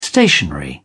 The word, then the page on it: stationery: British English